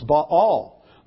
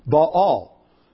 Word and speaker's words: Baal: The god Baal? No, it's Baal. There are two syllables in the word. Baal. And if you have a self-pronouncing Bible, you'll see there's an accent dividing those syllables.